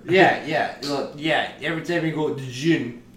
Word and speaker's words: djinn: a spirit or a djinn possess, like sealed within it. – It's like Django Unchained. The D is silent. It just says djinn.